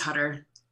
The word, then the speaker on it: Hutter: And real quick, how do you say your last name? Is it Hutter? – Yeah, it's Hutter.